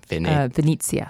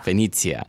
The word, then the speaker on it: Venezia: How would you say that? – Venezia. – Venezia.